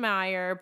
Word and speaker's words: Mayer: She says Susan Meyer believed, which is spelled Mayer, but Susan Meyer believed that the punishment should fit the crime.